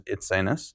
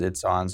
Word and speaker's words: Itsines: Yeah, so the proper pronunciation is Itsines. – Right. – But everyone says Itsines, so we usually just kind of, we let them say it, because it's kind of awkward to be like, we're introducing Kayla...